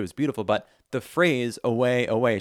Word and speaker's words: aue: So it's just spelt A-U-E, but it's pronounced away.